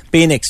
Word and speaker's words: Penix: Penix, il faut bien le prononcer. – Ah oui. – Penix Junior. – Okay. – P-E-N-I-X.